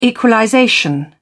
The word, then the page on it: equalization: British English